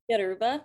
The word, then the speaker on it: Yoruba: And it was, is it Yoruba? – Yoruba.